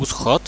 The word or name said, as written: uschod